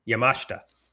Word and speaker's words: Yamashita: You know, I say that name all the time, and I just uh, said it wrong. It's Yamashita. So a lot of speculation from,